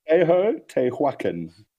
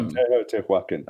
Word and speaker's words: Teotihuacan: How do you say it? – Teotihuacan. – Teotihuacan. – Yeah, Teotihuacan.